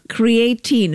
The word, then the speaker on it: creatine: Creatine. Is that how it's pronounced? – Yeah, creatine. Yeah.